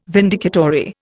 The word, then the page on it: vindicatory: Indian